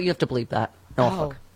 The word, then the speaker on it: Norfolk: Oh, there were some out of town people that used to read ads on commercials on Norfolk radio stations, and they would pronounce it really, really Ooh. – Incorrect. Yeah. Nor. F-U-C-K.